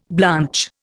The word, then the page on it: blanch: Indian